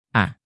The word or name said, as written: a